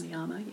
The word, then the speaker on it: pranayama: I know that you do a lot with you're an amazing, beautiful teacher of the breath and pranayama, I think is how it's pronounced. – Pranayama, yeah.